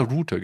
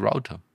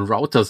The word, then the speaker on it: Router: In Deutschland sagt jeder Router, gell? Das heißt eigentlich Router.